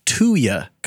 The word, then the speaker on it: Tuya: When you say it like 2-year convert, but it's 2-year convert. – T-U-Y-A convert.